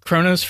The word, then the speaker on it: Kronos: A few of them include Aeon, which represents eternity, Moria, which is fate, and I think I'm going to butcher this name, Kronos. – Maybe not. That one's right. – Kronos for time.